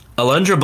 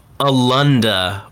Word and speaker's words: Alundra: So Alundra Blaze does like a promo before this highlight video. Actually, they both do. But Alundra mispronounces her own name. – Yep. – She calls herself Alunda Blaze.